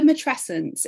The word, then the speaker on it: matrescence: So the word matrescence, it's very similar to adolescence, and you kind of pronounce it, it's kind of similar if you think of it like that.